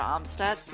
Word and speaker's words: Amstutz: Well, you can find me on all social media platforms at Sandra Amstutz.